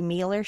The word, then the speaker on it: Meiller: That's The Larry Mealer Show. And Mealer is spelled M-E-I-L-L-E-R, as someone with a difficult to spell last name myself.